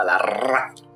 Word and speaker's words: Larraz: Claro, me gustaría también ver cómo muchos pronuncian Pepe Larraz en Norteamérica. – con doble R y Z, Larraz.